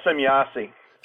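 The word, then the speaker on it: Semjase: As a contactee, you had contact with Semyasi. Am I saying the name right? – Yeah, Semyasi. – Semyasi. – That's a German pronunciation.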